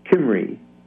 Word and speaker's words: Cymru: Yes, C-Y-M-R-U, which is pronounced Kimri.